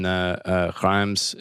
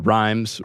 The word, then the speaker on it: Reims: And Clovis is baptized in Reims, if I can, it's always difficult to pronounce it the way that the French do, but Reims, we could say, in the Reims Cathedral.